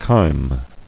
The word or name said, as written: Chyme